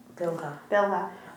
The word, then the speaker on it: Bilhah: Bilhah, for a maidservant.